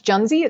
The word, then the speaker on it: Junzi: That is called, I believe it's pronounced Junzi. It starts with a J, J-U-N-Z-I.